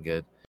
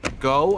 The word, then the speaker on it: Gough: Denise Go, I think is how you pronounce her last name. It's Denise G-O-U-G-H. No, G-O-U-G-H.